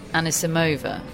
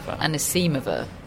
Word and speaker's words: Anisimova: She said she wants her name to be pronounced the American way. – Which is Anisimova. – Anisimova. – And not Anisimova, which is the Russian way.